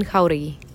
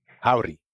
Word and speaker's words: Jauregui: My name is Yasmin Jauregui. My name is Yasmin Jauregui. – Howdy.